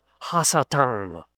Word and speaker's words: Ha-Satan: The name is Satan with a definite article, which is H-A. It's pronounced Ha-Satan.